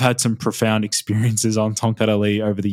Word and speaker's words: Tongkat Ali: So it's T-O-N-G, Tong, and then Kat, K-A-T. And then it's space, and then it's A-L-I. So Tongkat Ali.